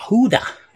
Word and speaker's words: Judah: Judah, Huda, actually, how it's pronounced in the Hebrew.